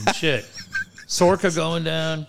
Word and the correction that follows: Soroka: How do you say that kid's name? – Soroka. – Soroka.